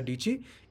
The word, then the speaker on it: Dietschy: tagline, her tagline of her channel is My name is Sara Dietschy. It rhymes with Peachy.